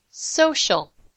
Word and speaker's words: social: Social. The C sounds like SH, sh.